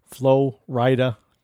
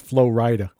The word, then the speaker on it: Flo Rida: Blige, Kelly Clarkson, Usher, Soulja Boy, Trey Songz, Jesse McCarthy, Katy Perry, Nelly, Monica, Ludacris, Sean Kingston, Flo Rida, Dr. Dre. I know I'm saying that incorrectly. It's Flo Rida. I do it for dramatic accentuations.